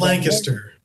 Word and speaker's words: Lancaster: Well, I tell you, I love, is it Lancaster? Am I saying it correctly? – It's Lancaster.